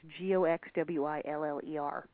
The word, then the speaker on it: Goxwiller: Goswiller, I don't know how to pronounce this. It's Goxwiller because it's kind of up in the German border area. – Goxwiller. – Goxwiller. It probably is because it's eastern France where everything is very Germanic as well.